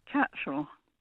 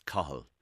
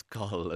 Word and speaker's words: Cathal: How do you pronounce C, A, T, H, A, L? – Catchall. – That's Cahill. – Borderline cruel. – That's Cahill.